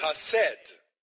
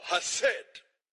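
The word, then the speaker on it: Hassed (חסד): E no Novo Testamento hebraico, ou desgraça diz Hassed. Tem que pronunciar com som gutural. Hassed. Diga Hassed para alguém.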